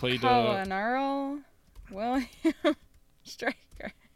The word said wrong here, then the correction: colonel: Colonel? You didn't just say colonel? – But that's how it's spelled. – Kentucky Fried Chicken, courtesy of Colonel Sanders. – Well, why would they put an R in there if there's no R in the word?